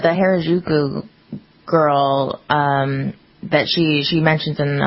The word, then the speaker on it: Harajuku: How do you, it's spelled H-A-R-A-J-U-K-U. Harajuku.